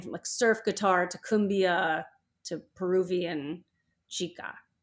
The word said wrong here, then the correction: chicha: Mm. Chicha. How do you say C-H-I-C-H-A?